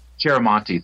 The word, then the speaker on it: Chiaramonte: And I'm going to pronounce this incorrectly, but the inker was Charlamenti. You know what I'm talking about? – Chiaramonte. – Chiaramonte, thank you very much.